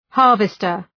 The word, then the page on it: harvester: ‘hɑ:rvıstər